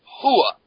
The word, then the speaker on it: Rua: There's no R in it. Hua. – I'd beg to differ. – Yeah, well, it's pronounced as an H. – Yeah, it's silent. It's Hua.